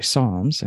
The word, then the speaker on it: Psalms: And they quickly let me know that it's actually Psalms and the P is silent.